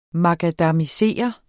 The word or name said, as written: makadamisere